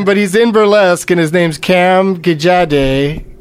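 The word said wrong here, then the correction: Gigandet: Gigande, I believe it's Gigande.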